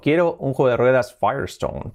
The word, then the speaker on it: Firestone: Vale, no es Firestone, se escribe Firestone, pero ¿cómo se pronuncia? Pues la pronunciación es, fijaos que es Firestone. Fire, fuego y piedra. Firestone. Firestone. No tiene nada que ver.